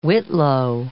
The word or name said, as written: whitlow